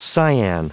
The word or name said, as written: cyan